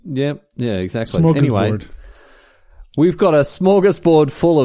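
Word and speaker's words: smörgåsbord: Welcome to our northern and it's actually a smooth gorse board because the A with a little O above it makes an O sound.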